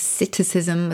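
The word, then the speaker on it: psittacism: It's called a sitticism with a silent P.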